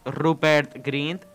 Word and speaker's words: Rupert Grint: Pues está Ronald, eh, cuyo actor es Rupert Grint.